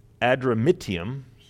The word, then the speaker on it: Adramyttium: And embarking in a ship of Adramyttium," that's exactly how you pronounce that,